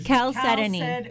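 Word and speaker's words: Chalcedony: Calcedony. – Calcedony. – Calcedony. – Write that down, Pancho. – Yeah, the H is silent.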